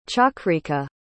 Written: chakrika

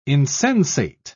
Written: insensate

